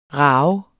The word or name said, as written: rage